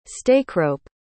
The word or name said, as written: stakerope